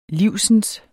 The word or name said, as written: livsens